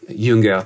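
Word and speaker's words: Jünger: And Junger, I think he's, Junger, to pronounce it the German way, I think he sees that respect.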